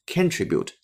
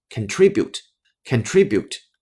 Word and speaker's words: contribute: 很多同学遇到这个单词，喜欢把重音放在第一个音节，包括我自己在原来的时候，也经常把这个重音放在第一个音节，错误的读成了 contribute，那是错的。它的正确发音重音呢是在第二个音阶，叫做 contribute，contribute。咱们再来一遍，contribute，这个才是正确的声音。